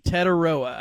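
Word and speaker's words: Tetairoa: Yeah, Teteroa. I had to look up the pronunciation guide from the school, so I don't want to mess it up.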